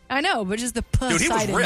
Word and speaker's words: Poseidon: Poseidon is correct. – A not silent P that I feel like should be silent.